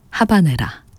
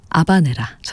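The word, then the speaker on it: Habanera: 비제 오페라 까르멘 가운데 하바네라, 아바네라 전해드렸습니다. 고띠에 카피송의 첼로, 파리 취임버 오케스트라의 연주였습니다. 원어 발음에는 또 아바네라라고도 또 저희가 소개해드리곤 하는데요.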